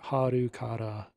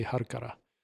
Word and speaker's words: Halkara: They spell it in the English subtitles as H-A-L-K-A-R-A, but it's like Harukara in Japanese. So I don't know. Somewhere in that range, however you want to pronounce it in your own head, I'm going to say Harukara.